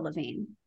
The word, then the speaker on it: Lavigne: I just pronounced it like Avril Lavigne. – That's what I did too. And I was reading it. I was thinking, Lavigne? Yes, this is going to be another back-to-back silent G's?